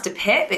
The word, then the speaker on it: De Pijp: I've actually stayed at the sister hotel, which is the Sir Albert, which is in an area called, I think it's pronounced De Pijp. It's spelled P-I-J-P.